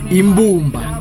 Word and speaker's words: Imbumba: Mispronounced his song. It's Ibumba. It's called Ibumba. Imbumba. Imbumba. Imbumba. Imbumba. The name of the song is called Imbumba.